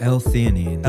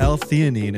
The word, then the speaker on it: L-theanine: It's a hypoallergenic patch that's sort of like microdosing a powerful blend of melatonin, valerian root, CBD, ashwagandha, how do you pronounce this shit? – L-theanine. – L-theanine